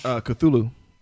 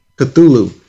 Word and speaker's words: Cthulhu: Cthulhu. – Cthulhu.